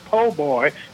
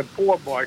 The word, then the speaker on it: po' boy: You originally pronounced the word po' boy, and I see you've just amended it to poor boy correctly.